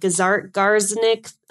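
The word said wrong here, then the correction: Zeigarnik: effect? – Zygarnik. – Zygarnik effect. Okay.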